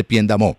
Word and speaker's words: Piendamó: Bueno, resulta que Luis Enrique, queridos oyentes, es el gobernador del Cabildo Indígena de la María de Piendamó. Luis Enrique, lo dije bien, lo pronuncié bien. – Sí, sí, es resguardo indígena guambiano o misac de la María, municipio de Piendamó, Cauca.